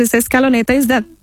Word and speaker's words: Scaloneta: Did I say Scaloneta? Did I say that right? – Yeah.